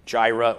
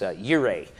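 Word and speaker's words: Yireh (יראה): Now, you're noticing this morning it's Yahweh Jireh, or actually, all of you will now know how to say this. It's Yahweh Yireh in Hebrew. It's Yireh, not Jireh, okay?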